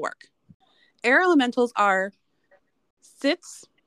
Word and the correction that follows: sylphs: I think that's how you pronounce it. It's S-Y-L-P-H-S. They are said to be small, invisible entities with wings. So I.e. sylphs. That's it.